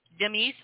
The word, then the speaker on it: Demis: Okay, this is by that Greek singer, Demi Roussos. – Demis. – Oh, you pronounce the S in the Greek?